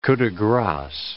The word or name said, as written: coup de grace